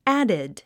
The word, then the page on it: added: ǽd; アァデッド